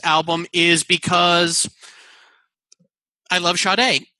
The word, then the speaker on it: Sade: But today, we're talking about the Sade album, Diamond Life. – Yeah. – Sorry, Sade. I said it wrong the first time I said it. Sade album, Diamond Life, just from 1984.